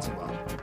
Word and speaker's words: Zihuatanejo: Now that you're back, maybe you can help Marty and J-Riff with the pronunciation of Zihuatanejo. – Marty was saying Zihuatanejo. – That's not what I said. I said Zihuatanejo. – Most people there locally just call it Ziwa.